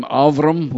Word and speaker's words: Avram: I like to call him Avram, which is the Hebrew pronunciation.